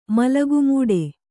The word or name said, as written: ಮಲಗುಮೂಡೆ